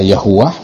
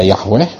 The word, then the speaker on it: YHWH: Nah, YHWH itu bacanya gimana? Ya kan? Itu bisa dibaca Yahuwah, bisa dibaca Yahweh, bisa dibaca Yehovah, ya.